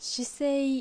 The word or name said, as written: Shisei